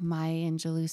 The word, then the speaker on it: Maya Angelou: Like Maya Angelou says, that's how you say her name, says these beautiful things all the time.